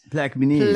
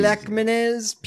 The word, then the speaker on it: Plaquemines: Along with Jefferson, St. Bernard, and how does one pronounce this? Plaquemines? Plaquemines? – Plaquemines. – Plaquemines. P-L-A-Q-U-E. Plaque. – Go fuck yourself. – M-I-N-E-S. One word.